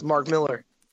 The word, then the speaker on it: Millar: Yes, which is how Nick and I felt when we found out that Mark Millar is not the way you pronounce his name. – Yeah, it's Mark Miller.